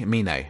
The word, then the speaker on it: Mine: Is it Mine? Do they call him Mine? – Mine. Okay.